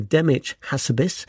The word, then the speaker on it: Demis Hassabis: Now, I should ask Sir Demis's forgiveness because I've just mispronounced his first name. It's Sir Demis Hassabis,